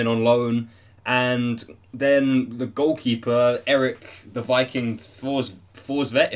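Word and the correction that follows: Thorstvedt: Torsvet came in December.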